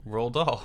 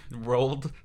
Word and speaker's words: Roald Dahl: How do you say his name? – Roald Dahl. – Roald? – Yeah, it's like... Rolled. – Rolled. – Roald Dahl. I never pronounced the D in his first name. – Roll? – It's like Roald Dahl.